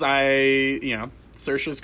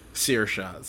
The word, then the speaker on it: Saoirse: It's pretty disappointing because Saoirse's great.